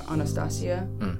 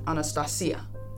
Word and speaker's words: Anastasia: She's Greek, so you pronounce it a little bit different. Normally people just call her Anastasia, but Anastasia, which I think is beautiful.